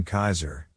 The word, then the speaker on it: Caesar: Thus, his name is pronounced in a similar way to the pronunciation of the German Kaiser, Kaiser, or Dutch Kaiser, Kaiser.